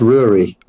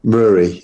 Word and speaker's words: Rury: It's meant to be pronounced Rury. – Rury. Okay. – Rury, yeah. Okay. Like Rory with a U, I suppose, you know.